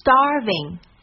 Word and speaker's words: starving: 其次我们来看一看 starving 它的发音，首先卷舌音在第一音节上，重读也在第一音节上 star，紧接着是一个咬唇音，这个音在中文发音中是没有的哦，所以初学者会被各种相近的音误导，最常见的就是发成 w。